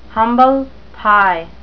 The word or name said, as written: humble pie